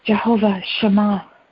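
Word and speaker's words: Jehovah Shammah: And Ken and Debbie, please let me know if I pronounced that correctly. Jehovah Shema. – That's right.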